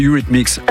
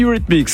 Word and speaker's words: Eurythmics: Le suivant, vous avez hésité, vous m'avez dit, comment ça se prononce ? C'est Eurythmics. – Oui, c'est ça. – C'est Eurythmics.